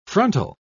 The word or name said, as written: frontal